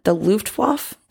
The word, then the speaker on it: Luftwaffe: In 1941, the Luftwaffe, did I say that right? – The Luftwaffe.